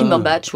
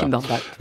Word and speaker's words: Cumberbatch: Benedict Cumberbatch, c'est ça ? On le prononce comme ça ? – Cumberbatch, oui, voilà. Cumberbatch.